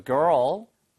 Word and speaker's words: girl: 이 발음은 자주 해드리겠지만 걸, G-U-L-L, 걸, seagull, seagull 할 때 우리가 갈매기라고 할 때 그거는 걸 이거고요. 이거는 girl, girl 한 다음에 Earl 점지를 땡기면 돼요. Girl.